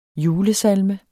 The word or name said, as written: julesalme